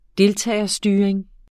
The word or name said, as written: deltagerstyring